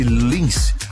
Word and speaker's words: LNNCE: Escreve-se dessa maneira, mas pronuncia-se Lins.